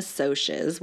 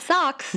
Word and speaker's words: Socs: The book follows two rival groups, the Greasers and the Socs, which is the short form for socials. – Not socks. – Not socks, which is how I pronounced it in my head for the first about 20 pages of the book.